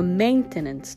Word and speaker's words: maintenance: この maintenance という単語ですが、目にアクセントを入れて発音していきましょう。